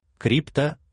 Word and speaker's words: крипто: крипто, что это перевод. – Все материалы на сайте blockchain24.pro можно прослушивать в аудиоформате. – Крипто, ударение на «и», это составная часть таких слов, как криптография или криптовалюта.